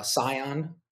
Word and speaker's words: scion: I think the word scion, scion. – I think it's pronounced scion, but I know exactly what you mean.